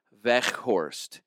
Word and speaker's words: Weghorst: You'll likely hear people saying Veghorst Veghorst. Okay. Which is mostly correct. It's spelled W E G. Uh huh. but the Dutch G is more like the Scottish C H.